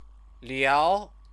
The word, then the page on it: liao: chinese